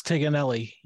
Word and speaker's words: Tignanelli: It's Tiganelli, and you can't butcher it any more than it's been butchered throughout my life.